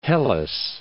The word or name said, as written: Hellas